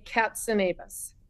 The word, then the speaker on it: Katzenavis: Yeah, it's like a cat, but with a K, Katzenavis.